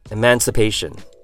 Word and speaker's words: emancipation: Emancipation uses a short E sound, the word man, a short I, and just like the word earlier, stress the syllable right before the shin. So in this case, that would be the pay sound.